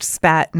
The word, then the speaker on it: SPAT: And it is pronounced SPAT, not S-P-A-T.